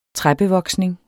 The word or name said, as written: træbevoksning